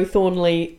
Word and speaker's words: Thornely: So I'm always Joe Thornely, all one word, E-L-Y. – J-O-T-H-O-R-N-E-L-Y.